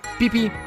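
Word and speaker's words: BBIBBI: Escrita como Bibi, pronunciada como Pipi,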